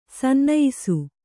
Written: ಸನ್ನಯಿಸು

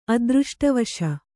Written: ಅದೃಷ್ಟವಶ

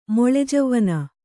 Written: ಮೊಳೆಜವ್ವನ

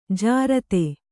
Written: ಜೋರತ್ತು

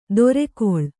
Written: ದೊರೆಗೊಳ್

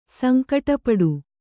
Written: ಸಂಕಟಪಡು

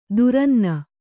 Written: ದುರನ್ನ

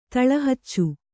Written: ತಳಹಚ್ಚು

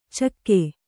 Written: ಚಿಕ್ಕೆ

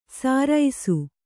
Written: ಸಾರೈಸು

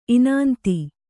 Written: ಇನಾಂತಿ

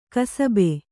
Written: ಕುಸುಬು

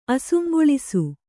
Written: ಅಸುಂಗೊಳಿಸು

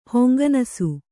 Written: ಹೊಂಗನಸು

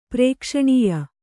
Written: ಪ್ರೇಕ್ಷಣೀಯ

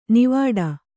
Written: ನಾವಾಟ

